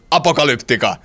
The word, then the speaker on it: Apocalyptica: That's how you say it. Angry. Apocalyptica.